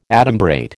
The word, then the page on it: adumbrate: American English